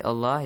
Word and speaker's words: Allah: Yes, if there is a kasra sound before the word Allah itself, mm-hmm. – So the lam in the letter in the word Allah is pronounced lightly, like la.